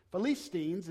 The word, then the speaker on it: Philistines: Philistines is the way it's properly pronounced.